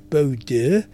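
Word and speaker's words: Bodø: It was a really strong wind, but it just carved straight across to Bordeaux. Yes, I have to pronounce it like that. You'll think it's spelt Bordeaux.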